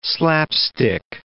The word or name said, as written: slapstick